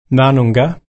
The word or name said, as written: narnungga